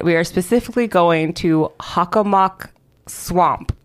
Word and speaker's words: Hockomock: O-M-O-C-K. Hold on, someone spell that back to me. Did I do that right? – There's no A's. H-O-C-K-O-M-O-C-K. – Yes. Hockomock. Hockomock. But that's how they say it. They say Hockomock.